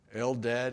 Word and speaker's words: Eldad: But back in the camp, Joshua found out that there were two men who have great names, by the way, Eldad and Medad. And that is how you pronounce them.